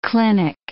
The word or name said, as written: clinic